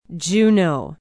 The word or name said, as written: Juneau